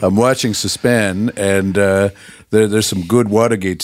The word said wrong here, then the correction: C-SPAN: Listen, when you say Suspan, it is like nails on a chalkboard. It is C-SPAN. – What? – You're saying it wrong.